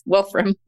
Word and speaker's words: Wolfram: Yes, Wolfram.